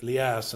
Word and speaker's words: Elias: The name Elijah in Greek is Elias, and it's pronounced in Arabic Elias and so forth.